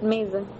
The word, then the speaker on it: mesa: In Portuguese, it's spelled the same way, but you say it Mesa.